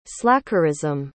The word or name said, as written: slackerism